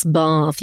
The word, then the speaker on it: Bath: I loved, yes, Bath.